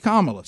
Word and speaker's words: Kamala: That's her name. It's Kamala. Say it right.